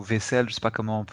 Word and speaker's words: Vicel: Vicel, ça se prononce, j'avais déjà vu ça.